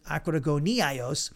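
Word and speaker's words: akrogoniaios (ἀκρογωνιαῖος): Now, the adjective akrogoniaios, that's how you pronounce it, akrogoniaios, is translated cornerstone.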